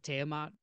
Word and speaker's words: Tiamat: and Tiamat.